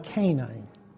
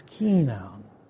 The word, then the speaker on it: Kenan: But many people think Kenan and the Canaanites are the same because they pronounce Kenan Canine. And it's not his name, it's Kenan.